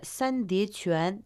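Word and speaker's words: 三叠泉: А сейчас давайте разберем название сан де чуан. Сан означает три, произносится в первом тоне. Де – это ступень, уступ, читается во втором тоне. И чуан – это родник, произносится тоже во втором тоне.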